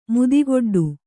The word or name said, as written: ಮುದಿಗೊಡ್ಡು